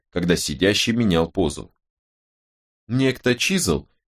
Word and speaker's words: Чизуэл: Моя фамилия Чизл». – «Как пишется?» — спросил Робин, чтобы не ошибиться. – «Чи-зу-эл. Джаспер Чизл.